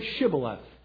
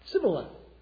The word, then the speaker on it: Shibboleth: Guard says, "Okay, all right, before you cross, say Shibboleth." He say, "Sure thing, Sibboleth." He probably said, "Sure thing," not "sure thing." He couldn't pronounce the sh sound.